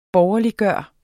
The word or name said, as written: borgerliggøre